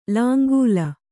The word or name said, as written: ಲಾಂಗೂಲ